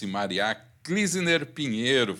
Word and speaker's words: Mariah Klüsener Pinheiro: Ah, vou resumir primeiro em arquiteto e urbanista e pós-graduando em neurociências e comportamento pela PUC-RS, Maria Klisner Pinheiro.